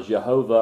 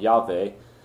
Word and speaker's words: Yahweh: that is the divine personal name of God, Yahweh, or Jehovah, or Jehovah, sometimes gets pronounced, but it's probably more like Yahweh.